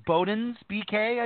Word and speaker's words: Bodens BK: If I mispronounced the club's name, was it Bodens BK, I believe? – Yep. You got it right.